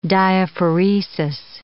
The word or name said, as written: diaphoresis